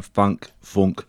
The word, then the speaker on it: Funke: I'm never sure how to pronounce her last name. Funke, it's F-U-N-K-E.